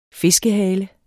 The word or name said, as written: fiskehale